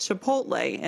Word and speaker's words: chipotle: And I was like, huh, that L is after the T. So is it pronounced chipotle? – Chipotle. – And not chipotle, like I've been saying it.